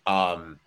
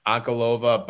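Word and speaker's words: Ankalaev: Listening to you talk has convinced me I'm going to lock in Ankalova. Did I say it right? – Yes. – Ankalova by decision plus 240.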